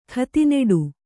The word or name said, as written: ಖತಿನೆಡು